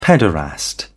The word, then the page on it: pederast: British English